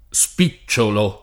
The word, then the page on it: spicciolo: lingua italiana